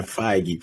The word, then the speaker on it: Feige: F-E-I-G-E? – Yeah, Feige. – Oh, man. Is that how you pronounce his name? – Yes. – I was just sitting here pronouncing it like beige, feige.